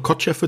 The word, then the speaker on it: Kotcheff: Regie hat dort Ted Kotcheff, ich schätze mal Kotcheff wird das ausgesprochen,